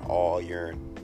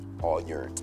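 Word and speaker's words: All Yourn: This song is called All Yearn. And that's spelled Y-O-U-R-N. All Yearn.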